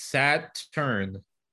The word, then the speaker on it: Saturn: Saturn is two syllables. It's Saturn.